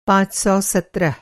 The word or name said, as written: पाँच सौ सत्रह